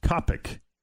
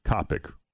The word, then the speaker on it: qapik: And the word is Kopic, which I'm probably mispronouncing, but how's it spelled? – Q A P I K. So I'm pronouncing that Kapik or Kapik.